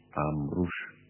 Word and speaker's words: Amrouche: Antes que los oyentes piensen que Taos, Margarita, Taos se escribe como suena, pero hay un segundo apellido, Amrouche, que se escribe hispánicamente Amrouche. Taos, Amrouche.